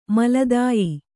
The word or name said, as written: ಮೇಲ್ದಾಯ